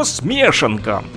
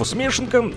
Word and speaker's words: смешанка: Вначале было слово «смешанка», ударение на букву «е» этого русского слова смешанка история такова.